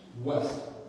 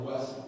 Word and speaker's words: Wuest: W-U-E-S-T. Mr. West. West. Phonetically, it sounds like West, but it's W-U-E-S-T.